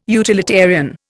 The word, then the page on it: utilitarian: Indian